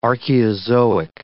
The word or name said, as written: Archaeozoic